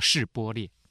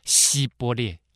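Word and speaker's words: 示播列: 啊，不是的话，接着就问：“ 你说示波列啊，就是发一个音叫示波列啊。” 以法莲人因为咬字不正确，所以呢就会说希波列，示波列，他会念成希波列。所以呢，示跟希他们分不清楚啊。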